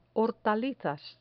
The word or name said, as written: hortalizas